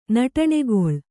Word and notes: ನಟನಕಲೆ: naṭana kale

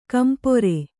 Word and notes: ಕಂಪ್ರ: kampra

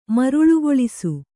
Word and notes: ಮರುಳುಗೊಳಿಸು: maruḷugoḷisu

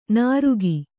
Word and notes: ನಾರುಗಿ: nārugi

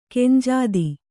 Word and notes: ಖಂಜಾತಿ: khanjāti

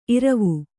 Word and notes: ಇರ್ವು: irvu

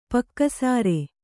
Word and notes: ಪಕ್ಕಸಾರೆ: pakka sāre

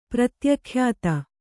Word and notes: ಪ್ರತ್ಯಖ್ಯಾತ: Kannada

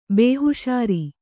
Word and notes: ಬೇಹುಷಾರಿ: bēhuṣāri

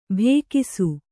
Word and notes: ಭೇಕಿಸು: bhēkisu

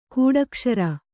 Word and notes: ಕೂಡಕ್ಷರ: kūḍakṣara

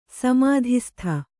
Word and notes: ಸಮಾಧಿಸ್ಥ: samādhistha

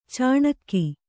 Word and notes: ಚಿಣಿಗಿ: ciṇigi

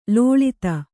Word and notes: ಲುಳಿತ: luḷita

